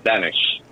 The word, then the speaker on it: Benesh: It's not that hard. You just... The trick to it is just say it. There's no fancy, emphasis on anything. You just blurt it out exactly as it reads. – All right, Derek Benesh? – Almost, Benesh.